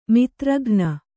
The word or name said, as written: ಮಾತ್ರಾಗಣ